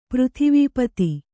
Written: ಪೃಥಿವೀಪತಿ

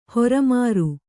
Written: ಹೊರಮಾರು

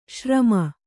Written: ಶ್ರಮೆ

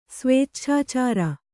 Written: ಸ್ವೇಚ್ಛಾಚಾರ

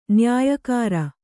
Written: ನ್ಯಾಯಕಾರ

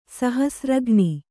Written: ಸಹಸ್ರಘ್ನಿ

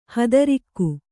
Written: ಹಾದರಿಗ

